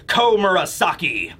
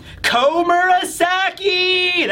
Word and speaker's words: Komurasaki: Okay, this will be One Piece, chapter 1041 review, titled, Komurasaki. It doesn't sound right when I say it that way. Komurasaki! That's better. You gotta say it like Orochi.